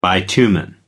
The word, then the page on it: bitumen: American English